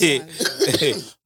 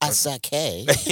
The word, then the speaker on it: Asake: It's Asake. But you're not wrong. I mean, with an American, that's how an American would pronounce it. – Asake. – Yeah, Asake. – Like a sake.